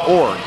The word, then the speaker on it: Iorg: The last name was I-O-R-G, and they pronounced it Org.